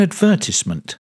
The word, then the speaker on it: advertisement: An advertisement. The word advertisement has four syllables. Ad-ver-tis-ment. The stress is on the second syllable.